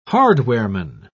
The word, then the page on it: hardwareman: British English